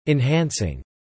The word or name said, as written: enhancing